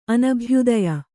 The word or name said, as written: ಅನಭ್ಯುದಯ